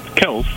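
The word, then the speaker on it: Kelce: It's Kels, spelled just the same as Jason and Travis. And we pronounce it Kels.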